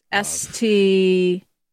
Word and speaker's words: Sturtevant: Sturtevant.